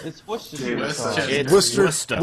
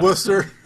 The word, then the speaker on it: Worcester: What's that stuff like? Worcestershire? Worcester? Westchester? – It's Worcester. – Worcester. You know, also interesting. – Worcester, you asshole.